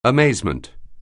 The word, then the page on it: amazement: anglais